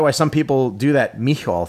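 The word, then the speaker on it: Micheal: In Ireland, it's M-I-C-H-E-A-L and is pronounced Michal.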